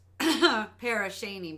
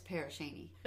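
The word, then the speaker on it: Pere Cheney: Okay, so it's written Pear and then Cheney, as in Dick Cheney, the guy that, the vice president that shot somebody in the face. But it's pronounced Parashaney because we're Americans and we don't pronounce things correctly.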